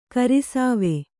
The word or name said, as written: ಗುರುಸೇವೆ